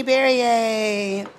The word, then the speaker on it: Berrier: I am Tiffany Berrier. Rhymes with Perrier, those that have never known how to say my last name.